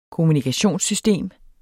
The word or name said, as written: kommunikationssystem